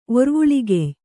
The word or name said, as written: ಒರ್ವುಳಿಗೆಯ್